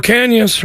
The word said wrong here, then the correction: Ocañas: right there. I'm saying that right. Right, Jude? – Ocañas. – Ah, I'm getting there. Ocañas. Got it.